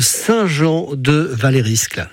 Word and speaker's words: Saint-Jean-de-Valériscle: Jean de Saint-Jean de Valériscle. C'est ça ? Je me trompe pas dans la prononciation, oui. – Jean ? – Oui, oui, c'est très bien, c'est ça.